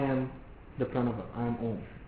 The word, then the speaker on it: Om: The word Om consists of three syllables, A, U, and Ma.